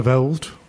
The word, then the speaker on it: Alderweireld: Alderweireld.